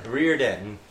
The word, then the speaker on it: Reardon: It's Reardon, but with a dun. – Okay. – Yeah. – There's a D. – There is a D in the middle of...